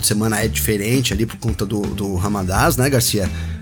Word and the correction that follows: Ramadã: Ramadã. É isso mesmo? Eu acertei. Ramadã, né? Ramadã. Então, para não deixar o nome errado aí, mas vai ser no sábado, né?